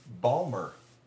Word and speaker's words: Baltimore: They pronounce their city Balmer.